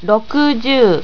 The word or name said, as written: rokujuu